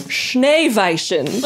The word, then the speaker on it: Schneeweißen: I said, how is this pronounced? They texted it back phonetically. Drum roll, please. Schneeweißen.